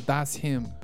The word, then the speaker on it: Thatshymn: The artist is called Thatshymn, but it's spelt That's with an S and then H-Y-M-N.